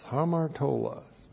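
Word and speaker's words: hamartolos (ἁμαρτωλός): Looks like that in the Greek, H-A-M-A-R-T, long O-L-O-S, hamartolos.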